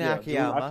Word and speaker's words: Akiyama: Monty, you're going to have to help me out here because it was Akiyama, the right way to say his name. – June Akiyama.